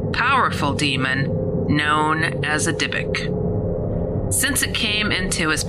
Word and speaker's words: Dybbuk: The story of the Dybbuk, pronounced Dybbuk, is rooted in centuries-old Jewish folklore.